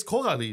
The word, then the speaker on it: Coralie: Call her Carolyn. It's Cavalli. – Yeah, you've just been butchering it the whole time. – It's Coralie. Excuse me. It's not. It's like Coraline. Coralie.